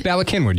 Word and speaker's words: Bala Cynwyd: Bala Cynwyd, yeah. That's how you say it.